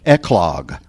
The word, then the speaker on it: eclogue: Eclogue for the church triumvirate. – Eclogue. – An eclogue is a musical term, a classical musical term, which means a pastoral, an English pastoral. So an eclogue